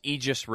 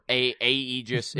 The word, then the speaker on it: Aegis: Yeah, 13 Sentinels Aegis Rim. – Okay, very cool. Thank you. – Or Aegis, that's spelled A-E-G-I-S. So it's like Aegis, yeah. – Aegis, depending on your regional accent.